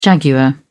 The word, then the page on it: jaguar: British English